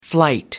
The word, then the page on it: flight: American English